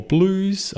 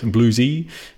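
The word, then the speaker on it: BlueZ: Uh, we had an update for blues or bluesy, depending on, yeah, well, I guess bluesy, uh, the American pronunciation.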